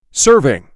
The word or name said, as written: Serving